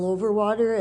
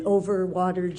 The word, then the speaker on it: Overwater: Overwater. It's S-H-E-L-L-E-Y, and then Overwater, just like it sounds.